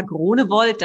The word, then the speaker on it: Groenewold: Ja, mein Name ist Ilka Groenewold. Das ist dann die deutsche Aussprache. Es schreibt sich aber holländisch O-E-N-E, wird aber nur O gesprochen, also quasi ein Dehnungsehe.